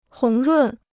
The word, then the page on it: 红润: hóng rùn